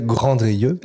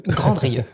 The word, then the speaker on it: Grandrieux: Maintenant qu'on en a fini avec Abel Ferrara, faisons son compte à Philippe Grandrieux. – C'est du français, Grandrieux. – Grandrieux, voilà.